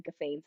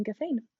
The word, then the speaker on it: Gaufin: I think I said your name wrong when we first started this. – That's okay. – Podcast listeners, exactly, so sorry. – I mean, it looks like Gaufin. – Oh goodness. Well, no one's perfect, right? – No, absolutely. You pronounced it perfectly.